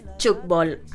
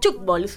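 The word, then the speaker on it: Tchoukball: Es un poco, no es Chukbol, así como como se pronuncia. Se escribe un poco diferente, pero lo voy a deletrear. Es Thukbawl. Chukbol.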